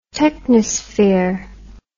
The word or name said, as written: technosphere